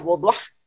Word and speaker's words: boudoir: La fotografía Boudoir, que diría una amiga que se fue a Francia, que se pronuncia Boudoir.